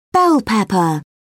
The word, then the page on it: bell pepper: British English